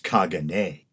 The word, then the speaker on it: Caganer: But the tradition is that of displaying a figure known as, it's spelled Caganer. C-A-G-A-N-E-R, but I believe it's pronounced Kagane.